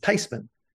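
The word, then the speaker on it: Theismann: She says, actually, the correct pronunciation is Thiesman, and they're closer than what we have now.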